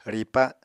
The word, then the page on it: ripa: Apatani